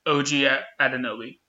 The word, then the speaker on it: Anunoby: OG Anunoby.